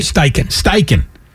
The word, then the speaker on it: Steichen: Did I say that right? – Steichen.